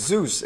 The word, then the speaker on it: Zeus: He's like, "It's Zeus. If you say it wrong again, I'm gonna hit you with a lightning bolt or something."